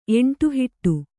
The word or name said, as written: ಎಂಟುಹಿಟ್ಟು